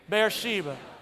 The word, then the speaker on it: Beersheba: Beersheba.